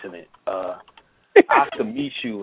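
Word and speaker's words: Micheaux: Oscar Meshaw. His last name is spelled Michaux.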